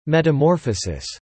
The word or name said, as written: metamorphosis